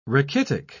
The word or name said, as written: rachitic